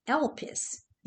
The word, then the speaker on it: elpis: Now, in the Greek, the word hope is pronounced elpis. You spell it E-L-P-I-S.